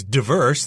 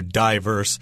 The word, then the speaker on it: diverse: And I should mention diverse could also be pronounced as diverse. That's what I learned, but uh, I do hear diverse said quite frequently.